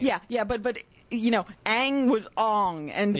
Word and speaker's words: Aang: And so, so the character of Aang is Aang. – Yes. – That's how they say it. That's what it is.